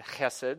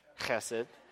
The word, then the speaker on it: chesed: It's my favorite word to say because you get to spit people in front of you. So all of you say it right now, chesed, and wipe the back of your head.